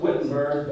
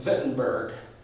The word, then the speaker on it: Wittenberg: You all pronounce it Wittenberg, but in German it's Wittenberg.